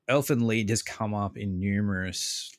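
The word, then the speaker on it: Elfen Lied: I think, yeah, you will never get the jump scares, but I want to try, and I feel like Elfin Lied, if I'm saying that correctly. – You are saying that.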